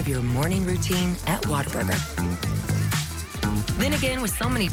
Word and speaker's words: Whataburger: I get made fun of for the way I say it, but it's pronounced Whataburger, and it's not Whataburger. I know that's what people think I say, water, but I do not. I say Whata. Whata Burger. Whata Burger. Whata Burger. There you go.